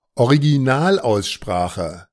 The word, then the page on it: Originalaussprache: German